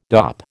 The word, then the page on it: dop: American English